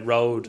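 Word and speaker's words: Rode: How do you pronounce this? It looks like a Swedish word to me. I would say road. – Yeah, road. Road. As in road, yeah.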